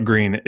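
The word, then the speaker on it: Gruene: For those that don't know about Green, it's spelled like Gruen. It's it's G-R-U-E-N-E, and that's nope.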